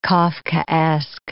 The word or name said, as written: Kafkaesque